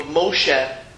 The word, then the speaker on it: Moshe: But when you see someone often, such as the name of Moshe, we know that's the right way of pronouncing it.